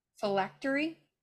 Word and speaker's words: phylactery: Phylactery.